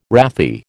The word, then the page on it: wrathy: American English